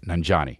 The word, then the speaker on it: Nanjiani: I should really say his name right. Nanjiani. There we go.